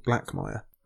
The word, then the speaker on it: Blackmire: And there's also a house called Blackmire. Rhymes with Blackfire.